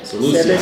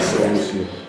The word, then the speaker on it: Seleucia: Seleucia. Seleucia.